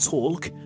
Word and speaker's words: talk: The word is talk, not talk.